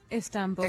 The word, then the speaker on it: Istanbul: Istanbul.